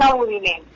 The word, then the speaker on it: Abudinen: ¿Se pronuncia Abudinen o Abudinen, doctora Karen? – Karen Abudinen. – Con tilde en la E.